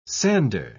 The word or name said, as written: sander